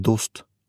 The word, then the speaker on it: dost: Og der var Kåre Se på deg, dust, Peter, hvordan går det? Kåre klappet meg på hodet. Det heter dost, og det er pashto.